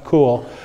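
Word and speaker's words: Koukl: Eric Kuchel's, I'm pronouncing his last name wrong. Cool, maybe? Eric Cool? It's spelled Kuchel, but I think it's just pronounced Cool.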